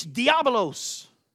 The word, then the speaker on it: diabolos: You look it up, and hey man, those that speak Espanol, they say it right. It's diabolos. Diabolos.